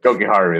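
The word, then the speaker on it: Jokiharju: Colin Miller, I can never pronounce his name correctly, or Jokeru, I think. – Jokiharu, there you go.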